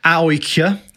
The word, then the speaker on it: AIK: And they said, basically, AIK in Sweden, or AOIK, as we learned they were pronounced when we were there.